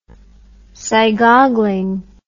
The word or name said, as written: sigoggling